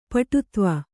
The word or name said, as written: ಪಟುತ್ವ